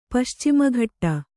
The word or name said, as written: ಪಶ್ಚಿಮಘಟ್ಟ